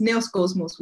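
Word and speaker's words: Neos Kosmos (Νέος Κόσμος): Can you say it the proper way? – It's Neos Kosmos, which means... Ooh. Yeah, it means new world in Greek.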